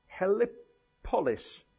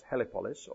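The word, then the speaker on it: Heliopolis: The sun god is better known today as, let me just make sure I pronounce this right, Helipolis. On is the city, and On is better known today as Helipolis or Sun City.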